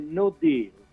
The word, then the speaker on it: no deal: Mi permetta anzitutto di correggere la pronuncia, ma lo faccio non per lei, ma siccome sento che gli italiani hanno trasformato il no deal in no deal. No, è no deal.